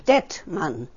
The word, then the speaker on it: Dettmann: Detman. – Can you spell that, please? – D-E-T-T-M-A-N. Detman. – D-E-T-T-M-A-N-N.